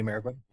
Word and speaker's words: Mariquin: So you have I imagine a more tame, man, how do you say his last name? Mariquin? – Andy Mariquin? – Yeah, there we go. I was like mannequin, but,